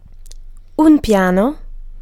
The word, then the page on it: flight: /flaɪt/